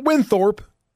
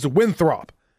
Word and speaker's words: Winthrop: Top-seeded Winthrop. It's not Winthorp, as a lot of people pronounce it. It's Winthrop. They cruised to the Big South Championship yesterday with an 80-52 win over Campbell.